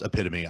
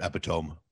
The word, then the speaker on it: epitome: And listen, American Gladiators was the epitome, which I understand it's pronounced epitome. I say it epitome. Get off me.